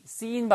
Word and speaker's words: seen: 길게 하셔야 돼요. He might have seen. seen 발음을 길게 하셔야지. 짧게 하시면 죄란 뜻입니다. S-I-N.